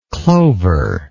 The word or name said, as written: clovery